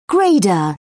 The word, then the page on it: grader: British English